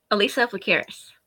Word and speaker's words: Alissa Plakaros: Alissa Plakaros.